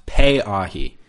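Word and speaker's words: Pe'ahi: This would be a good time to mention, I got an email from a listener correcting pronunciation on that surf spot, saying it's actually pronounced Piahi.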